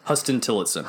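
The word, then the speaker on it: Huston-Tillotson: Also, Huston Tillotson, right? Is that how you say it? – Huston Tillotson.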